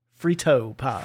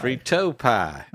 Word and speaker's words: Frito pie: I believe you want to pronounce it the Texan way, Frito pie. – Frito pie. – All the emphasis goes on the T.